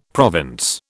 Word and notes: province: British English

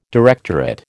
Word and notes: directorate: American English

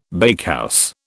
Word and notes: bakehouse: British English

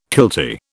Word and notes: kiltie: British English

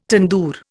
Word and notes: tandoor: Indian

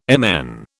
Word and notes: MN: British English

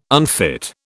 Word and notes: unfit: British English

